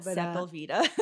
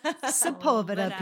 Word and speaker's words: Sepulveda: And it's not Sepulveda. – It is definitely not. And yet I'm sure I called it that too. Sepulveda, people.